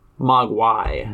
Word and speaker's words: Mogwai: Mogwai.